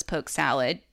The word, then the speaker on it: poke sallet: So it's pronounced around the South as poke salad. The word salad, ending with the E-T, it comes from an older form of English and refers to something like a cooked salad.